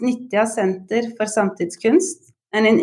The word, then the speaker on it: Nitja: It's called Nitja. – Nitja? No, Nitja. – No, it's not the ch. It's Nitja. So it's like ninja, but then you would exchange the N for a T.